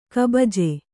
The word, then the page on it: ಕಬಜೆ: kabaje